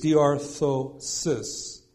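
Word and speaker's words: diorthosis: And that word reformation, and I'm sure it's been pointed out, it's only used once in Scripture, but it's, and I'll phonetically pronounce it. It's diarthosis.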